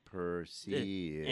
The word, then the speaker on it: Perseid: It's a long E. Perseid.